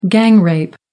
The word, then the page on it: gang rape: American English